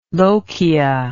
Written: lochia

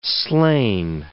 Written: slain

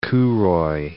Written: kouroi

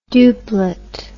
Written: duplet

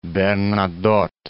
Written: Charles XIV John

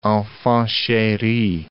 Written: enfant cheri

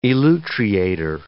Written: elutriator

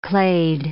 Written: clade

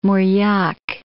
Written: Mauriac

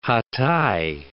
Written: Hatay

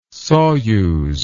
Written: soyuz

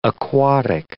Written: aquatic

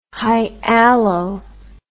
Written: hyalo

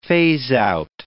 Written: phaseout

